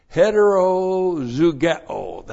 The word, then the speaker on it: heterozugeo: And the key word here in verse 14 is heterozugeo. That's the key word. Looks like that. Oop, got to put the H in there. H-E-T-E-R-O-Z-U-G-E long O. Hetero zugeo, a hapax legomenon.